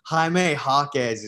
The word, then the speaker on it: Jaime Jaquez: Jaime Jaquez is how you pronounce it.